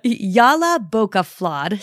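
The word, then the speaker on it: Jólabókaflóð: So there are a lot of very opinionated people online adamant that the word is pronounced one way or another, but Jolabokaflod seems to be the consensus, so I'm going to use that here.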